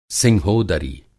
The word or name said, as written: सिंहोदरी